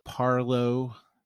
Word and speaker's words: Parleaux: Parlo Beer Lab. It's P-A-R-L-E-A-U-X. – Because it's New Orleans. Yep. – That's right. Of course, it's spelled L-E-A-U-X.